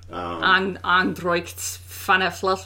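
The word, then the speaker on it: Ár nDraíocht Féin: Androicht Fana Fluff. – That's it, yes.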